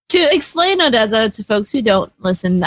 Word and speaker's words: Odesza: about Odessa really quick.